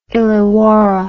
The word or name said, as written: illawarra